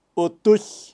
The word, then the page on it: utuy: Kaqchikel